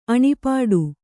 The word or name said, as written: ಅಣಿಪಾಡು